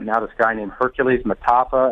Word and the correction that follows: Mata'afa: and I think I butchered his last name. – It's Mata'afa, man. Mata'afa.